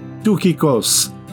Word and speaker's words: Tychicus: The performer in this case was more than likely Tychicus, pronounced in Greek, Tychicus, translation, he who is spot on, or just spot on for short.